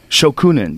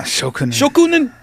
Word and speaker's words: shokunin: Shokunin, yeah. – Shokunin.